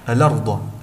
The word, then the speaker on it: الأرض: كذلك حرف الهمز فهو حرف مستفل، وقد فخمته في كلمتي الأرض وكلمة غطاءك، فالأرض تنطق هكذا الأرض.